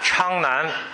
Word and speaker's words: Changnan: And the place used to be called Changnan. I guess the first translation or the merchants, they probably cannot pronounce it exact Changnan, or maybe the local accent is like China when they pronounce Changnan.